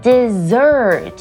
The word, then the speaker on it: dessert: OK，那有两个 S 的那个字是念 dessert，也就是饭后的甜点，以及一个 S 的 desert 则是指沙漠。但是你有没有发现，其实他们发音完全不同，虽然只有差一个字母，dessert 甜点，desert 沙漠，有没有觉得差很大呢？